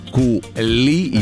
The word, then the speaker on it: кули: Так вот, вот это все три слова заимствованы из французского языка, поэтому ударение в них падает на последний слог. То бишь «конфи», «кули», еще есть «компоте».